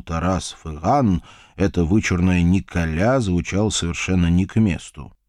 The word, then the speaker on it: Николя: Его звали Николя. Именно так на французской манер с ударением на последний слог он и предпочитал представляться людям, с ним ранее незнакомым.